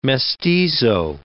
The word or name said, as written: mestizo